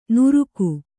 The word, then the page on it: ನುರುಕು: nuruku